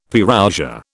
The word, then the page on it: Parousia: British English